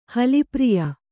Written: ಹಲಿಪ್ರಿಯ

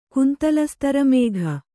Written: ಕುಂತಲಸ್ತರಮೇಘ

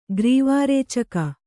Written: ಗ್ರೀವಾರೇಚಕ

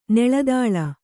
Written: ನೆಳದಾಳ

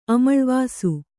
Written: ಅಮಳ್ವಾಸು

